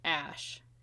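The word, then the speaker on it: Æ: But the A-E in the middle is pronounced Ash.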